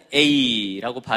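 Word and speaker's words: 에이 (εἰ): 하나는 에이라고 발음을 하고 또 하나는 에안이라고 발음을 합니다.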